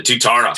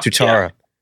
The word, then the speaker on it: Tuatara: Is it Tuatara? – The Tuatara. – Tuatara.